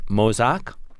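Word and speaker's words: Mauzac: And this is a white sparkling wine made from a grape, and I'm not sure I'm going to get this pronunciation correctly, so tell me if I'm right. Mozac? – Yeah. – Okay, got it.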